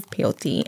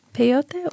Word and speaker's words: peyote: what is peyote and where does it come from? – Peyote, or peyote, people pronounce it differently, is a small spineless cactus native to Mexico and found in parts of the southwestern United States, primarily southwest Texas.